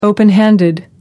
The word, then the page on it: open handed: American English